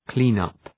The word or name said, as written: clean-up